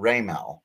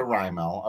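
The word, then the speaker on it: Raimel: Corrected us to Raymel. Okay. Okay. – Correct. – But in MLB The Show, and it's entirely an emphasis thing. It's entirely the emphasis. – The emphasis on the wrong syllable.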